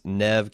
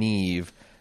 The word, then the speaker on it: Neve: said, I cannot stand to hear Aaron pronounce Neve Campbell's first name Neve when 300 million other Americans pronounce it Nev. I know you like to pronounce things your own way, but you got to fix this.